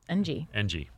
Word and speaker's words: Ng: Ng is there's no vowel. – Ng.